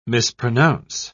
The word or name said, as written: mispronounce